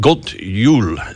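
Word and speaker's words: God jul: God Yule, G O D for good and J U L for Yule, like Yule tide, Christmas. so God Yule.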